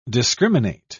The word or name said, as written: discriminate